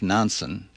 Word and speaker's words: Nansen: It was the brainstorm of a great Norwegian explorer, Fridtjof Nansen, who was five others, was the first man to cross Greenland on skis. – Rhymes with Johnson.